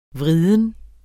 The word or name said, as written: vriden